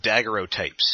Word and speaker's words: daguerreotype: We might as well be talking to them about daguerreotypes.